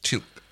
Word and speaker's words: Teal'c: It is pronounced just Teal'c. I mean, technically there should be a glottal stop in case of the apostrophe, I suppose.